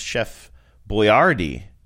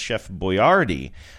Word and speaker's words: Boyardee: I think she was telling Genevieve, unless she was spilling poison in her ear, she was telling Genevieve that it was originally pronounced Chef Boyardee, which makes a little bit more sense, and now it's Americanized as Chef Boyardee.